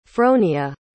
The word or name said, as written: fronia